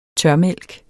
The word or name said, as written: tørmælk